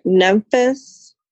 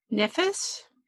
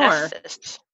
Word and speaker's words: Nephthys: Nemphis. No, that's not how you say that.